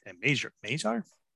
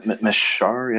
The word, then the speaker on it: Mesar: Well, both first round picks really, but the two Slav players there, Slajkovsky and Mesar. I'm still pronouncing it wrong. – Mesar, yes.